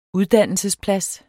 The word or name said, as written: uddannelsesplads